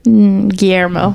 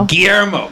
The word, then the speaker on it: Guillermo: But I had, you know, I've always just been kind of not really a huge fan of Guillermo del Toro. Did I say it right? – Guillermo. – Guillermo.